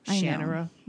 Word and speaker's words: Shannara: You know, he says that it's pronounced Shannara.